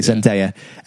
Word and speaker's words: Zendaya: Oh, no, but I'm really, really Zendaya, sorry, Zendaya. Yeah.